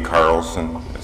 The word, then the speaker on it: Carlson: Carlson, S-O-N, Chairman of the Fairbanks North Star Borough in Fairbanks, Alaska.